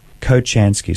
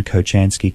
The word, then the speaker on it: Kochansky: Kochansky. So I'll spell that K-O-C-H-A-N for November, S-K-I. That name again, Kochansky, K-O-C-H-A-N for November, S-K-I.